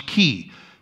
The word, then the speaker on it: chi: Chiasmus comes from the first three letters are C-H-I. And it bugs me to no end because it comes from the Greek letter chi, which you pronounce key.